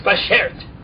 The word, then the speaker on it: bashert: Well, I think you're overextending it, but that is the right word. It's bashert. – Shert. – Bashert. – Cut it down a bit. – Bashert, yeah. It's like bashert.